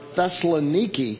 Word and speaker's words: Thessaloniki: But he changed the name to Thessalonica, or Thessaloniki, as it is pronounced, because that was his wife's name, and he loved her.